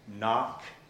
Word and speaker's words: knock: Did you ever wonder why knock has a K, a silent K, in the beginning in the English language?